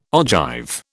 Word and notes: ogive: British English

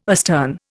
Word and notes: astern: Indian

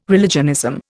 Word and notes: religionism: Indian

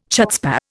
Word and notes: chutzpah: Indian

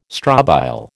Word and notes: strobile: American English

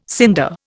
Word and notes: cinder: Indian

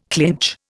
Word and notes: clinch: Indian

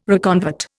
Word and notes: reconvert: Indian

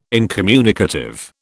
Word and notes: incommunicative: British English

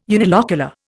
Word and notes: unilocular: Indian

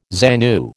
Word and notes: ZANU: American English

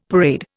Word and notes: parade: Indian

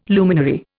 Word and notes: luminary: Indian

